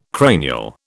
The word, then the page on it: cranial: British English